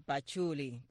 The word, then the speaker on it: pachulí: De pachulí. Pachulí, el pachulí. – Aquí está puesto con el acento en la I.